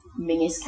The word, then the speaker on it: Menzies: Now, British listeners may be aware of the politician Mingus Campbell, whose forename, Mingus, is spelled M-E-N-Z-I-E-S.